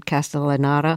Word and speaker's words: Castellaneta: I should mention the voice of the public radio host was Dan Castellaneta. Am I saying his name right? – Castellaneta, yeah, from The Simpsons, yes.